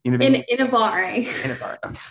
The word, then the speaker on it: Innovare: Innovare. It's Italian. So just Innovare. Think Rome, Italian, Innovare.